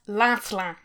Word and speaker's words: Lancelin: But it is pronounced Lancelin. – Lancelin? – Yeah, it's only the two Lancelin. – Oh. – It's only the two syllables.